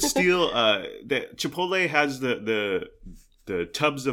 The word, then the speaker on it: Chipotle: Uh, so Dominic Brascia, uh, also uh, noteworthy is uh, the way he pronounced it Chipotle. He couldn't pronounce it for whatever reason, and his mind and his brain can process it, which some words, I'm that way too, but uh, he would pronounce it Chipuli and Chipatople and Chipalulu and all this stuff.